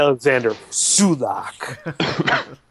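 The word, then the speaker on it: Salák: He wants me to correct you on the pronunciation. It's yeah. Alexander Sulak.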